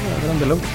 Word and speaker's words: Adrian Belew: Fíjate, después lo sustituyó Adrian Belew. Así se pronuncia, Adrian Belew.